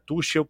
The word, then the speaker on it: Tuchel: Tuchel, para quem gosta da pronúncia do CH brasileiro, é, mas eu tento sempre é, reproduzir né, a pronúncia original ou mais próximo daquilo ali.